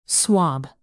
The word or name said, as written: swab